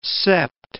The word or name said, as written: sept